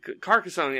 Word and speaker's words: Carcassonne: Carcassarone. Wait, Carcassonne. Yeah, Carcassonne. Yeah, that's the correct way to pronounce it.